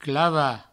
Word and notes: clavar: clava

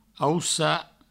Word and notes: aucar: aussa

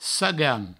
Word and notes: caganh: sagan

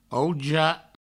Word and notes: auja: Provençal

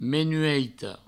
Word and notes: menueita: menueito

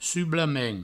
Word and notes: sublament: provençal; sublamen

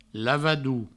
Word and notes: lavador: lavadou; Provençal

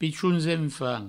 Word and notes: pichons enfants: pichouns enfant

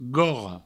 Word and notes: gorra: gorro

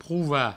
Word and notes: provar: prouva; provençal

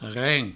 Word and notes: reng: Provençal

